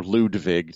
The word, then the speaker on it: Ludwig: Even though my grandfather's middle name was actually Ludwig. Or Ludwig, to hear my brother pronounce it. – Well, I mean, that's the proper German pronunciation.